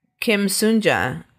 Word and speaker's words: Sun-ja: Our antagonist, our bad guy, is Kim Sunja. And this is Kim Sunja. – Kim Sunja and Kim Sunja. – Ours is spelled S-U-N. And this victim is S-O-O-N.